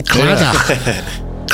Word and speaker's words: Claddagh: It's a Welsh-looking name, Claddagh. – So that's how you pronounce it? – Yeah. It'd be like a kind of huh at the end.